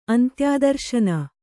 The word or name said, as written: ಅಂತ್ಯಾದರ್ಶನ